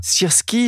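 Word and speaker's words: Sirski (Сирський): Je ne sais pas si je prononce bien son nom, vous le faites bien mieux que moi. Qui est ce nouveau général et que peut-il apporter ? – Alors, le général Sirski, ta prononciation, Pierrick, est très bonne.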